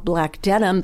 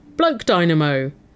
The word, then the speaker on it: BLKDNM: Like there's a company in San Francisco called, I believe it's called Black Denim, but it's spelled B-L-K-D-N-M. – It's a risk. If you weren't familiar with Black Denim, you might insert the wrong vowels. Bloke Dynamo. Balked name? – Yes, that is a liability.